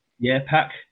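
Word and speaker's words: PAOK: Is it PAC, Mike? – I think that's how you say it. – Yeah, PAC.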